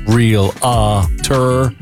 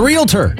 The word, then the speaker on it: realtor: There's no real-a-tor. It's a realtor.